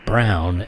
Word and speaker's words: Braun: It looks like Paul Braun when written, but it is pronounced Brown, and don't piss him off.